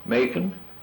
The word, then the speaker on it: Macon: Rhymes with bacon. – Is it Atlanta? Marietta? – Okay. How about, it's Ronnie Thompson is the mayor there. – Macon.